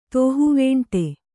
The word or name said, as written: ತೋಹುವೇಂಟೆ